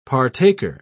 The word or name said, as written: partaker